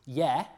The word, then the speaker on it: ge: The only major difference is that the prefix that's spelt G-E, the German ge prefix, which in Old English, because of palatalization, is pronounced ye, so the ye prefix.